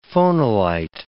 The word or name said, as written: phonolite